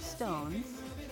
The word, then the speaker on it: SixTONES: On January 22nd, 2020, Snowman and Stones, that's spelled S-I-X-T-O-N-E-S because there are six of them, but it's pronounced Stones because Japan, released a double A-side, Imitation Rain by Stones and DD by Snowman.